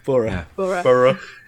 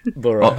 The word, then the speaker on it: borough: Is it not borrowing? – It's not borrow. Borough. – Borough.